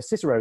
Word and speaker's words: Cicero: which is Cicero's tweets. Although, of course, Cicero would have pronounced his name Cicero, because all C's in Latin were hard.